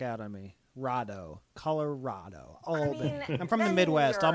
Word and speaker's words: Albany: As a New Yorker, I feel I have to say it's pronounced Albany, not Albany. People have been pronouncing Albany on TV, and I'm like, start it. It's Albany. Sorry.